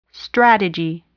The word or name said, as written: strategy